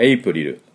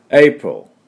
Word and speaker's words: April: では、今日は 4月は日本語発音ではエイプリルですけれども、実際の発音はエイプロっていう発音です。